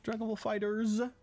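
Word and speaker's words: Dragon Ball FighterZ: Dragon Ball Fighters zoals je het eigenlijk moet uitspreken.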